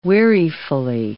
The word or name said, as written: wearifully